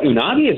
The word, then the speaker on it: Unagi: Let's talk about, I don't know how to pronounce the name of this scooter that is launched in Chicago, UNAGI. Is that Unagi? – That's right.